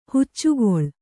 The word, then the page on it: ಹೆಚ್ಚುಕೊಳ್ಳು: heccu koḷḷu